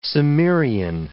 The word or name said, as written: Cimmerian